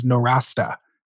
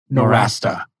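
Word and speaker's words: Nowrasteh: the proper pronunciation of his last name is Norasta. – Norasta.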